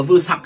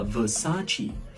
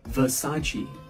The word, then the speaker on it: Versace: hay đọc là vừa sặc hay là vừa soạc như đấy nhưng mà thực ra hãng này nó đọc ba âm và rất sang như sau nó đọc là versace versace versace